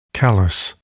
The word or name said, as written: callus